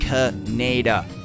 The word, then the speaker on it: Kaneda: One name, one name you've been dogging and dodging all these years, and it's pronounced Canada.